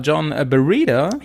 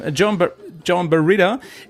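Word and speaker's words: Joan Barreda: Es gab einen Tagessieg und der war von dem Honda-Fahrer John Barreda. – Jo. – Wird das so ausgesprochen, ja, ne? – Jo, so wird das ausgesprochen. – John Barreda, er hat einen ersten Platz geholt und zwar vor Sam Sunderland von GasGas, der Teamkollege von Sanders.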